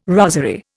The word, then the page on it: rosery: Indian